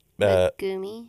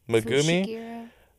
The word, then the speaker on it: Megumi: Megumi. – Uh, Megumi. Is that it? – Megumi, a student from Tokyo Metropolitan Magic Technical College, say that three times fast.